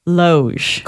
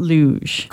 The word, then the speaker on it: loge: So you're saying it's Loge. – Correct. – Not Luge.